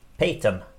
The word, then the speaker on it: Peyton: Ja, och en annan elev som heter Ivana Patien. Patien? Peyton? Peyton! Tack! Men ni vet hur det är med mina uttal, jag vet, förlåt.